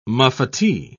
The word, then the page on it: muffetee: British English